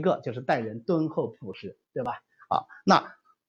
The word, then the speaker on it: 厚朴: 当然这里呢，呃，我们要学中药的话，应该读厚朴是吧？啊，但是这是个多音字，所以你把它改成另外一个音，厚朴就变成那种对德行的要求。